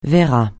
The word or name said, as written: verrat